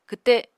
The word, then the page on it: kwte: Apatani